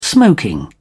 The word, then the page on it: smoking: British English